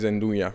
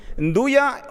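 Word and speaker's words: 'Nduja: So the correct pronunciation is Ndugia. – Ndugia on my side, but on the in the side of Vibo Valencia, people might call it Ndugia. But on my side is Ndugia.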